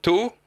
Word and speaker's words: Tau: Comment on prononce Tau, T-A-U ? – On prononce Tau. – Tau, voilà, faut mettre un peu d'accent.